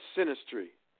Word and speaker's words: synastry: It's called synastry.